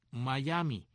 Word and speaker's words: Mayaimi: Miami, que se escribe M-A-Y-A-I-M-I, Miami, Miami. Y ahí es donde sale justamente,